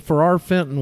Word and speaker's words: Ferrar Fenton: Well, Ferrar Fenton was an Englishman, F E R R A R F E N T O N, exactly like it's spelled,